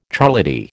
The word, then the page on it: charlady: American English